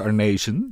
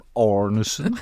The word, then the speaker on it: Arnesen: Frank Arnezen in plaats van Arnesen.